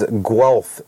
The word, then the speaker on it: Guelph: Xander says Guelph is how you say it.